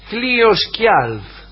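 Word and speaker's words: Hliðskjálf: Como reina de los dioses, solo ella tenía el privilegio de sentarse sobre el trono Hlios Hjalf, o yo lo pronunciaría Hlios Hjalf, porque la J por ahí va como I, Hlios Hjalf, cuyo dueño era Odín.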